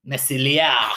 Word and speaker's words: Meslier: French, so you have to say it like he's French. – Meslier.